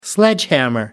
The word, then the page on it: sledgehammer: American English